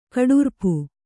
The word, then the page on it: ಕಟ್ಟಾರ್ಪು: kaṭṭārpu